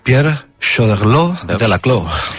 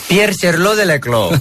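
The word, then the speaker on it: Pierre Choderlos de Laclos: Pierre Cherlo de la Clos. – Pierre Cherlo de la Clos.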